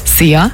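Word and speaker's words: Szia: Einfach ausgesprochen wie unser Energy Star. See ya.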